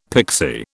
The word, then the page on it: pixie: British English